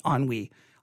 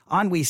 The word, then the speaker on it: ennui: That sounds like there's a we there to me. Ennui. Ennui sounds correct to me.